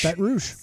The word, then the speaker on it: Baton Rouge: Baton Rouge? – Baton Rouge.